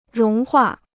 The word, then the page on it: 融化: róng huà